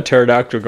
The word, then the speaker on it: pterodactyl: Oh, why can't you hear a pterodactyl going to the bathroom? – Why? – Oh no. Oh no. – Because the pee is silent. Because the pee is silent. Wow. Because pterodactyl spelled PT.